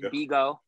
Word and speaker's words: Bigo: I pronounce it Bigo. – Okay. – And some people say Bingo, but I say Bigo, B-I-G-O. – B-I-G-O. – Yeah, it's no N, so I just put Bigo.